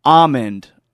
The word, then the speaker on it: almond: You don't pronounce the L like you do, like you don't in salmon. It's actually almond or almond.